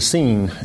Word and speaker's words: SIN: That has connotations in English that are not inappropriate, but it was that's pronounced the scene in Spanish.